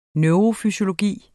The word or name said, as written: neurofysiologi